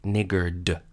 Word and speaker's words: niggard: The word is niggard. Let me be very clear about that D at the end of the word. It's spelled N-I-G-G-A-R-D. It comes from medieval Scandinavia, so it's a very old word, and it means someone stingy or ungenerous or miserly.